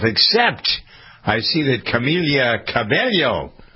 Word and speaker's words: Cabello: Cabello.